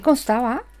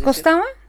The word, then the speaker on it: constaba: ¿Y no es costaba? – Con. – Constaba. – Exacto. Y tú estás diciendo costaba. – No, constaba. Ah, puede ser que lo haya pronunciado mal. – Es que no te siento la N.